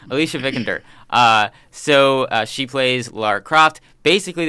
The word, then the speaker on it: Vikander: If Alicia or anybody from the film is listening, or anybody worked on the film is listening, we're sorry if we butcher her name. – No, you got it right. – Vikander? – Mm-hmm. Vikander. – Okay. – Big fan right here. So Vikander.